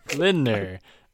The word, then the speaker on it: Lindner: speaking of the coalition talks, actually, there's some polling that shows that Christian Lindner, Lindner. In German, you pronounce all the letters. That's how German works.